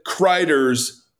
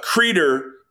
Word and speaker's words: Kreider: However, I have to say that you pronounce Alan Kreider's name wrong. It's not Kreider, it's Kreider, like cider.